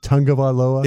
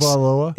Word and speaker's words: Tagovailoa: Is it Tagovailoa? – It is. That's how you pronounce it. Yes. 856. – Tagovailoa.